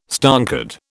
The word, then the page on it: stonkered: British English